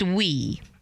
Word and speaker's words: oui: And it's spelled O-U-I, but it's pronounced we.